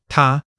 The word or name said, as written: ta1